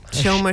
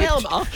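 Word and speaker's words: Chelmsford: Shelma Sherwood. – Shelma. – Did you go soft on this? – Is it a silent C? – Is that a local thing?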